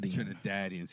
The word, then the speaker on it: Trinidadian: It's a soft ah, so it's Trinidadian. – Trinidadian.